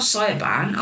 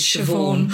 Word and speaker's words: Siobhan: And they're like, it's not Cyaban. I was like, but that's how it's spelled. And they were like, it's Siobhan.